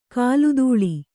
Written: ಕಾಲ್ದೂಳಿ

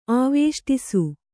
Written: ಆವೇಷ್ಟಿಸು

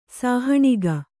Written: ಸಾಹಣಿಗ

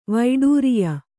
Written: ವೈಡೂರಿಯ

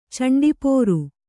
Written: ಚಂಡಿಪೋರು

